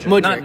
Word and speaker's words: Mudryk: Mudryk. – I need to get this right. – Mudryk.